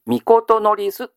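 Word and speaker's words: 詔す: みことのりすと読みますね。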